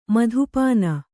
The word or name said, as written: ಮಧುಪಾನ